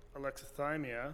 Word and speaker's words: alexithymia: alexithymia. I think I'm pronouncing that right. – Yeah, that's right.